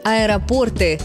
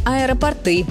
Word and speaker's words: аэропорты: Правильный вариант – аэропорты с ударением на «о», не аэропорты. Почему так? Все довольно логично. В слове есть часть «порт», и именно она тянет ударение на себя.